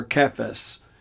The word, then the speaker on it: Cephas: And when James, Cephas, or Cephas, as it's sometimes pronounced,